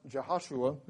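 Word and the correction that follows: Jehoshabeath: Is that correct? Jehoshabeth.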